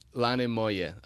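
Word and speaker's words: Lane moje: But most definitely, there's actually a song that features quite prominently, which is a Serbian song, Lane Mohe. If I'm you know, messing up the pronunciation there, which was the Eurovision song, right? – Yeah. – It was. – Lane Mohe. Okay. – That's right.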